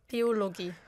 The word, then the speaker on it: Biologie: Biologie.